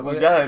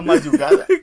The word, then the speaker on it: magullada: Eh, además que te lo dije, ¿mayugada o magullada? ¿Cómo se dice? Es verdad, que es mayugada. Y es como, no, tío, que tenía la, la mandíbula como magullada. – Claro, magullada, no mayugada.